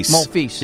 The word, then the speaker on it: Monfils: Monfils, or how do you say his name again? – Monfils. Yeah.